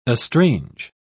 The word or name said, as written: estrange